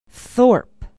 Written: thorp

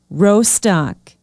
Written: rostok

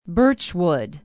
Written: birchwood

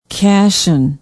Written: cassian

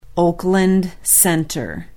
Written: oakland center

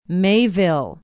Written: Mayville

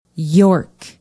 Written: york

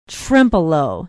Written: trempealeau

